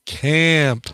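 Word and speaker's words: Caamp: I say it like that because it's got two A's. Camp. – Oh, weird. – If it was one A, I'd say Camp, but it's two A's, so I say Camp. The name of the song is Square One.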